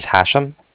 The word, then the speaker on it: Hashem: My name is Hashem. – Can you spell that? – Yeah, it's H-A-S-H-E-M as in mother at the end.